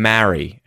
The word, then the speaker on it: marry: Be careful with its pronunciation, though. Don't confuse it with Mary, as in the name, which is the long air vowel sound, as opposed to the short e in Merry, or with the vowel sound a in marry, as in to marry someone.